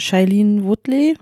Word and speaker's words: Shailene Woodley: Das ist eine relativ neue Komödie mit Jack Whitehall und Shailene Woodley. Habe ich sie richtig ausgesprochen ja.